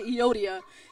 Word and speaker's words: Euodia: Euodia? That's what it's going to be tonight. Kind of sounds like yodeling, but we're just going to say Euodia.